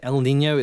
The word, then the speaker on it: El Niño: El Niño. Are we saying it correctly? – Yes, that's right. El Niño.